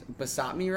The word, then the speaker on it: basmati: It's basmati rice. How do you say it? – Basmati. – I always say it's basmati. – B-A-S-M-A-T-I.